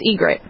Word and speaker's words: Ygritte: Is it Ygritte? – Yeah, okay, good. I wanted to hear you guess first. It's Ygritte, so you're pretty close.